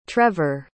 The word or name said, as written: trevor